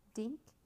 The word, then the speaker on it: Dink: Dink, Sammy repeated. That rhymes with think, pink, and stink.